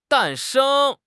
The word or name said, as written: 诞生